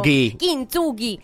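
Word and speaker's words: Kint Sugi (金継ぎ): Buongiorno, sì, perché in giapponese dovresti mettere l'accento Kint Sugi, sì. In ogni sillaba, sì, capito?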